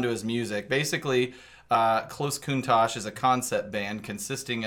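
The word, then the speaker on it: Countach: Is it pronounced Kuntosh? – Yeah, like the car.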